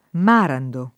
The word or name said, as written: Marando